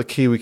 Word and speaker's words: Aki: Am I saying his name wrong all this time? – Yeah, well, there's got to be an O there, right? Because he's Irish now. – I get it. It took me a while. I was like, O'Key. I was like, is that the proper pronunciation? Okay, Bundy Aki is the final one.